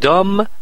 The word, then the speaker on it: dumb: او میخواست بازی کنه roles, نقش های serious, نقش های جدی, instead of, به جای dumb blonde roles, dumb, بی تلفظ نمیشه, احمق.